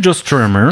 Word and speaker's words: Joe Strummer: Et puis juste avant, bah, c'était euh, Johnny Cash et euh, Joe Strummer. Je ne sais pas si je le prononce bien. – Joe Strummer Strummer Ouais, c'est Joe Strummer.